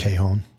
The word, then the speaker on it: Cajon: How do you say that? Cajon.